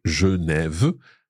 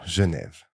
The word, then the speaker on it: Genève: So Genève, the first E in there is coming before one consonant, so it's je. Then Neve, it's got a grave accent, and then the silent E at the end.